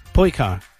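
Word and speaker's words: Pojkar: did I say it right, Porsche car, or not? – Oh, no, I have to get up and read it again. – P-O-J... – Yeah, K-A-R. Poikar.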